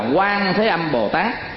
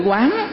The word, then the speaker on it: Quán: Đức Quan Thế Âm Bồ Tát mà thường là mình đọc là Quan Thế Âm Bồ Tát. Chữ đó là chữ quán.